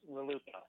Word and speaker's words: RLUIPA: How do you pronounce it, by the way? – I use the phrase RLUIPA. – Okay. – I know others say RLUIPA as well.